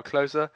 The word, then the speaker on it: Klose: Okay, so moving on to Bilal Dariush is up against Drakkar Klose, and that is pronounced right, yeah? Like Miroslav.